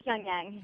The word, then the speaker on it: Pyongyang: How do you say Pyongyang? Is that it? – Pyongyang. – Pyongyang. – Yeah, well, okay. – That's how I say it.